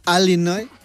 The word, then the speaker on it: Illinois: Sabes que hay, como aquí en España, hay acentos diferentes en Estados Unidos y los nativos del estado de Illinois lo pronuncian Illinois.